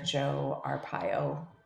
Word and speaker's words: Joe Arpaio: And someone had come to me with information that he was supporting Sheriff Joe Arpaio. That's how you say his name.